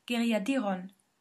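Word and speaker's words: Geiriadur: I think it's just he pronounces himself Owen, who sent me a very nice email saying that the U in what I pronounced as Geriadur dictionary last week is actually an E. So it's Geriadiron. So the app is called App Geriadiron, and our dictionary is Geriadir.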